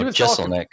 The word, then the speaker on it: Jeselnik: Oh, Jeselnik.